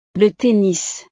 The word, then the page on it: le tennis: frances